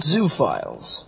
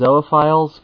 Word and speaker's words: zoophiles: What do you think about zoo files? Why... – Let's go back a little on that one. I think it's technically pronounced zoophiles, because I think that's the correct pronunciation of the prefix.